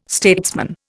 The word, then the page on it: statesman: Indian